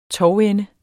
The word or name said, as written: tovende